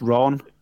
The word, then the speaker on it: Iran: But it's Iran, isn't it? It's not the Ran at all, it's Ron.